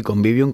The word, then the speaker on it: convivium: Los romanos, a ver si lo digo bien, convivium. – Sí, convivium, que de hecho hay muchas palabras que hoy utilizamos, vienen precisamente de ahí.